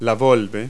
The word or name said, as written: volva